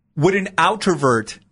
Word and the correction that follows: otrovert: I think it's otrovert. – Otrovert. – Like O-T-R-O.